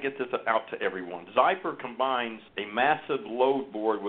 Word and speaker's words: Xypper: it's called Xypper and uh, it's it's spelled X Y double P E R, Xypper.